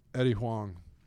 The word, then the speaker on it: Eddie Huang: My first real venturing into the land of Eddie Huang. Did I say that right? – I think so. – Eddie Huang.